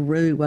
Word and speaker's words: roux: And I remember the first time a Mississippi girl heard the word roux, I went, ooh, what is that? And it's spelled R-O-U-X, which is a French word.